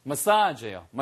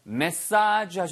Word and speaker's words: massage: 발음 주의하세요, 이거. 메시지 그러면은 전달하는 메시지고요. 예, 그 다음에 전달 사항인 거죠. 얘는 마사지예요. 마사지. 발음은 두 가지입니다. 메사지 하셔도 되고 마사지 하셔도 돼요. 그래서 저는 마사지를 더 선호하고요.